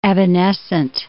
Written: evanescent

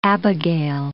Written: Abigail

